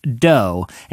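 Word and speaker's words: Dō: And it's spelled D-O with a little line over the O. Evidently, that means it's pronounced dough.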